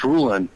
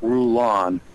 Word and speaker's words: Rulon: But, yeah, it's Rulon, not Rulon. I don't know. I think Big Love gets it wrong, too.